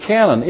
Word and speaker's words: canon: A canon goes boom, C-A-N-N-O-N. But there's a Greek word that if it were transliterated, sounds like the word canon. It's C-A-N-O-N. C-A-N-O-N